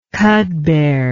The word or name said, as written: cudbear